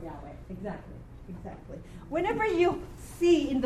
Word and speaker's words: Yahweh: Remember, the vav can be a wa. – Right. – Okay? That's why there is a Yahweh. Yahweh. Okay? Because the vav can be pronounced as wa or va.